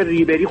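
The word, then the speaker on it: Ribéry: فرانک ریبری. – فرانک ریبری. بله. – خب متوجه شدم. ارتباط برقرار میکنن. اون میگه غ. – خب آلمانیام ر رو غ تلفظ میکنن، ولی فرانسوی ها قشنگتر تلفظ میکنن.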